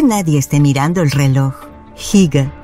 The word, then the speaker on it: Hygge: Se pronuncia más o menos Hygge. Creo que lo hago bastante bien.